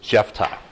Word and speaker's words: Jephthah: There's probably a lot of different ways you can pronounce it, but I'm going with Jephthah because I think that's a little more correct. There's really not a TH in Hebrew. That comes from the German transliteration of it, and then in English we make it a TH.